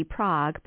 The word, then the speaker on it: Prague: It looks like the city Prague, but it's pronounced Prague in Oklahoma, which had been doing something very egregious.